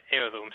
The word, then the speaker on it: Eòdum: No sé si ho pronuncio bé. Eòdum. – Sí, exactament, Eòdum.